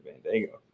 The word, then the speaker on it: Bandaogo: So you said Bandaogo.